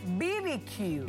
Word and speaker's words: BBQ: Recuerde, no se pronuncia barbecue. – Barbecue, barbecue. – Barbecue, es BBQ.